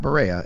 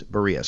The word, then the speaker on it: Berea: I said it wrong a few times and people let me know. Not Berea, it's Berea.